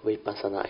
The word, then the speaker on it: vipassana: Vipassana is a Pali word. So vipassana got two syllables. We, then pasana.